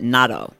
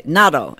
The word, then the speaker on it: NATO: And her husband is an Army Ranger who is currently working for NATO, which when you're in Italy, they pronounce it NATO. So like Italians would say when we were all together, like, oh, what do you do? And they would say NATO. And I'm like, what are you saying? And that's how they pronounce NATO.